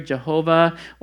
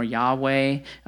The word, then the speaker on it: Yahweh: There's a Hebrew word here, Jehovah, or we tend to pronounce this more Yahweh, more of a Hebrew pronunciation, but Jehovah Jireh, Yahweh Jireh, it means God is our provider.